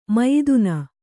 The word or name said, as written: ಮಯ್ದುನ